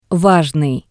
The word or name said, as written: vajnii